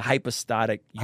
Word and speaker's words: hypostatic union: I think people struggle with the I'm going to use a big theological term, and you tell me if I say it wrong. – Yeah. – The hypostatic union. – Hypostatic union, yeah.